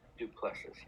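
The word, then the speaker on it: Du Plessis: Duplessis, yeah.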